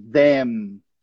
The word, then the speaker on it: them: I like to watch them. Eles, né? Então, a gente, como termina com M, a gente tem que fechar os nossos lábios no final. Them. E a outra, a gente não fecha.